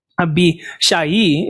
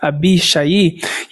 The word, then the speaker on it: Abishai: Abishai is how you would pronounce it in Hebrew. Abishai.